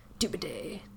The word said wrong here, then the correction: Doubleday: General Doobaday. But it's Doubleday.